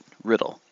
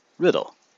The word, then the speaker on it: Riddle: That's right, Riddle. Not Riedley, not Riedla, not Riedel, not Riedel. Riddle, just like it's spelled.